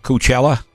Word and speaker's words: Coachella: How do you say it? – Coachella. – Oh, you have to say O? – Yeah, Coachella. – I say Coachella, and I'm fine with it.